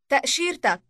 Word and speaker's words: تأشيرتك: تأشيرتك. تأشيرتك. – Note the "uk" ending.